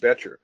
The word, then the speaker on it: Bettger: One of my favorite books is a book called How I Raised Myself from a Failure to Success in Selling by Frank Betcher. And Betcher was it's spelled Bettger.